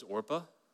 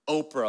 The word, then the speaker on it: Orpah: Her original name was Orpah. Did you know that? But people mispronounced her name on a regular basis. She was called Oprah all the time. Like, hey, Oprah. Oh, my name's Orpah.